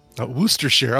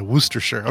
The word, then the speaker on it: Worcestershire: Worcestershire. Oh, I said Worcestershire. Yeah, Worcestershire. Okay, thank you.